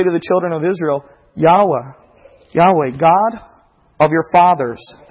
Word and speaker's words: Yahweh: People pronounce it different ways. Jehovah, right, with the J sound, or Yehovah, or Yahweh, or Yahweh. I don't know exactly 100% sure how to pronounce it, so I'm just going to ask you to excuse me, but I'm going to pronounce it Yahweh,